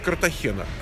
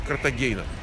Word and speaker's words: Картахена: Меня тут поправляют, что правильно говорить Картахена. Картахена говорят те, кто по-испански говорит. Вот они говорят Картахена. А если вы пойдете в словарь, там, как говорит Мерриам-Вебстер, да, и послушаете, как произносится Картагена.